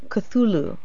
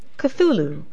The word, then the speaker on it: Cthulhu: Se pronuncia Cthulhu. Cthulhu.